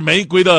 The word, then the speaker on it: 玫瑰: 还有一个咱们经常读错的就是“ 玫瑰” 这个“ 瑰” 字，它不读一声，也不读四声啊，而是奇葩的，它要读轻声。玫瑰啊，玫瑰，这才是玫瑰的正确的读音。